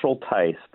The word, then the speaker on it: Schultheis: Very close, Schultheis, but yes, it works. Schultheis. I've heard a lot worse. – You know what? They did tell me Tice, and I just didn't do it right.